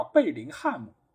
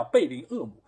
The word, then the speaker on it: 贝林厄姆: 所以现在大家可以看到一个官方的译名就叫贝林厄姆。为什么叫贝林厄姆？其实就是他们知道 H 不发音，所以省略了这个 H，按照英文的一个正确的读音来读的，对吧？